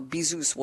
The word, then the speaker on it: Bezos: And on Wikipedia, it says the Z-O-S is pronounced Zeus. So Bezos will go with that.